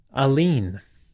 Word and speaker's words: Aligne: They released their third major release, Aline. That's spelled with a G in there. A-L-I-G-N-E.